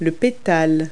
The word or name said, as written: petal